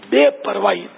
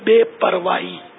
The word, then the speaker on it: بے پرواہی: اصل وہ لاپرواہی مستعمل ہے لیکن لغت میں اب جو ہے نا جو لکھا ہے گئی ہے، جو بے پرواہی، صحیح اس کا تلفظ ہے، صحیح لفظ، فصیح لفظ ہے بے پرواہی. ہی نہیں، ہاں نہیں آئے گا، حمزہ یا بے پرواہی.